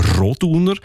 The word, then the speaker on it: Roduner: Also üblicherweise betonen wir ja Namen in der Deutschschweiz, die drei Silben haben, immer auf der ersten Silbe. Also Andereck, Zollinger, Bertschinger, Ochsenbein. In dem Fall ist es aber eher die zweite Silbe, die betont Roduner.